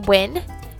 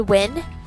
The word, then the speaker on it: Wyn: My name is Wynn, pronounced as Wynn, W-Y-N, Wynn. I'm going to repeat again, Wynn. A lot of people call me Wing, Wayne, Wine. They're wrong, but